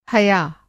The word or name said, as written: हया